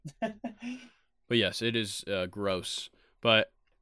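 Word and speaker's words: Groß: Yeah, and it's actually pronounced gross.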